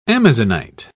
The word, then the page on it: amazonite: British English